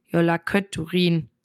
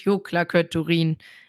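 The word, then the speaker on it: Jólakötturinn: Ich habe mir hier echt einen abgebrochen und mir ist aufgefallen, dass ich, als ich den Titel gesagt habe, habe ich die Katze falsch gesagt. Die heißt natürlich Jolaköturin und nicht Joklaköturin. War, hat sich einfach K eingeschlichen, das da nicht hingehörte.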